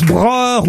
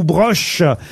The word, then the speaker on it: Broch: Alors, je sais pas si on prononce broer ou broche. – Broche.